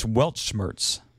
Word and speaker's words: Weltschmerz: It's just released as a single right now, but it's Welch March, something like that. I could never even pronounce the words anyway, so anyway, there it is, Garden of Remembrance from Fish. It's sitting here right in front of me. It's Weltschmerz. There we go.